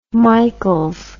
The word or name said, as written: Michaels